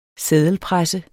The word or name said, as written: seddelpresse